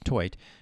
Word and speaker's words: Theut: And Isabel Toit, T-H-E-U-T, pronounced Toit, who is a grad student, led them with nearly 4 kills per set.